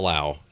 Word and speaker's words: Lowe: A lot of people spell it L-A-U or something weird. – L-O-W-E. – Yeah. So. Like Gordy. Was Gordy, the hockey player, a ho or a how? – Gordy Howe.